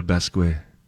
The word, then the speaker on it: Basquiat: Jean-Michel Basquiat.